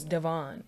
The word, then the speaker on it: Devonne: So Vonne is pretty much my first name is Devon. It's spelled D-E-V-O-N-N-E.